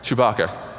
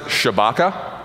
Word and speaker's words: Chewbacca: But it's hilarious because he pronounces it Shabaka, which is I mean sounds like a like a cologne or something, you know, like a like a women's fragrance, Shabaka, right? Um, that's how he pronounces it.